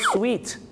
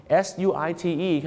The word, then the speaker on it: suite: ไม่เห็นหน้างงเลยครับ the suit suits you ก็แปลว่าสูตรตัวนี้เข้ากับคุณมากครับแต่ว่าพอคำว่าสูตร suit เพิ่มตัว e เข้าไปด้วยเนี่ยนะครับเหมือนกับอย่างเช่นที่ห้องโรงแรมที่เป็นห้องชุดมีทั้งห้องรับแขกด้วยเนี่ยชาวไทยไปบอกว่า I want the suit room I want the suit room มันไม่ใช่ห้องเก็บสูตรนะครับคุณผู้ชมห้องสูตรนะครับผมที่จริงแล้วต้องเรียกว่า suite suite ครับส่วนพี่น้องชาวไทยออกเสียงว่าห้องสูตรแต่ฝรั่งจะเรียกว่า suite